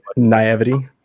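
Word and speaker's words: naïveté: Uh, for forgive my naivete. I probably pronounced that wrong. – naivety. – Naivety. Thank you but I just realized the last woman I dated was BPC, uh, BPD because of this video.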